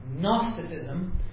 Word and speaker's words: Gnosticism: And the three that we're going to be looking at today are Docetism, D-O-C-E-T-I-S-M, Gnosticism, with a silent G at the beginning, and Marcionism, named after someone named Marcion.